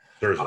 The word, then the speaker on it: Scherzer: Scherzer, that's it.